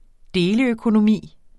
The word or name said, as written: deleøkonomi